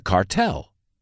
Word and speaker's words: cartel: Por ejemplo, he was a mule for the cartel. Él fue una mula para el cártel. Cuidado, la palabra cártel se pronuncia cartel en inglés y no cártel como en español.